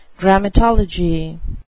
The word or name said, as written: grammatolatry